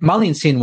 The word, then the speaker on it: Malintzin: But Malintzin was Marina, pronounced in Nahuatl. There's no R sound, so it becomes an L. And then the Zin is an honorific.